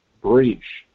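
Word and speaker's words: Bresch: His daughter, Heather Manchin, I believe it's pronounced Breach.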